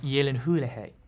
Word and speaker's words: Gyllenhaal: Is it pronounced Gyllenhaal? – Yeah. – Or Gyllenhaal? – It's pronounced Gyllenhaal. Gyllenhaal. Gyllenhaal.